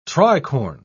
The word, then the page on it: tricorn: British English